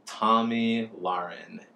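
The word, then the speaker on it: Tomi Lahren: Tommy Lauren. It is pronounced Tommy, like the boy's name, and Lauren, like laryngitis.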